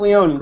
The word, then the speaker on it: Leone: Now, is it Chris Leone? How do you say it? – You said it right. Chris Leone.